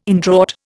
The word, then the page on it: indraught: Indian